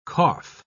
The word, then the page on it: qoph: British English